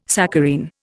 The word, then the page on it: saccharine: Indian